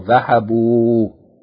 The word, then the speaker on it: ذهبوا: But this is for the masculine plural, ذهبوا. Yani he went, ذهبا. They went, masculine plural, ذهبوا. Okay, it says here, note that there's an aleph at the end of the word which is not pronounced.